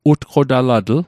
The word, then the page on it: utqotalatl: Mi'gmaq